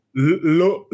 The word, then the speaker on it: Louisville: It's two syllables. Two syllables. Louisville.